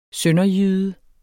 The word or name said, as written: sønderjyde